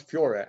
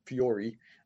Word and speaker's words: Fiore: But at least she helps me pronounce Fiore and not Fiori, my partner Agnieszka, who's Polish, by the way.